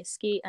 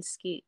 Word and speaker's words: Skeete: So if people want to find me on YouTube, you can literally just type in my name, which is Myah Skeete, and it's spelled like M-Y-A-H, and then Skeete is S-K-E-E-T-E.